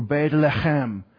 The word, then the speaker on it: Beit Lechem: Maybe you know that Bethlehem would better be pronounced in Hebrew, Beit Lechem, the house of bread,